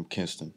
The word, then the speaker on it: Kinston: Kinston. Yeah. I'm saying it right? – Yeah. Kinston, North Carolina. They're going to kill you if you say it wrong.